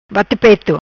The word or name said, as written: Bate peto